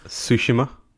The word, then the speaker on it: Tsushima: Simon, I think you'll find that it's pronounced Tsushima.